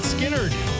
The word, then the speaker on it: Skynyrd: Skynyrd. I know that's how you pronounce it because they tell you that right on the album cover.